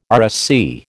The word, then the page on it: RSC: American English